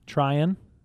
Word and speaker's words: Tryon: Like almost like I'm trying to explain this to you, but like drop the G, Tryon. – Is it T-R-Y-O-N? – Yes.